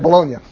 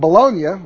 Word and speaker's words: Bologna: Yeah, that's it, Bologna. Okay. I hate mispronouncing things. The first universities were those of Bologna, which is in northern Italy, and Paris, which is in northern France.